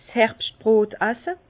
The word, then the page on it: 's Herbschtbrot asse: Bas Rhin; Herrlisheim